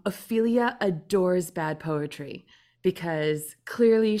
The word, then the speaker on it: Ophelia: And Ophelia rhymes with things like Celia.